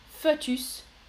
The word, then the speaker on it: fœtus: Fœtus, en français, on prononce toujours fœtus.